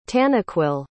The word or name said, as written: tanaquil